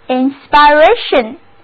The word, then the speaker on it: inspiration: 那么如果我们在 inspire 后面把一去掉，加上 ation 的名词后缀，那么单词 inspiration， I-N-S-P-I-R-A-T-I-O-N， inspiration，重音放在倒数的第二个音节上，单词就读作 inspiration。